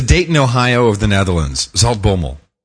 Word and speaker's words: Zaltbommel: It's Zaltbommel.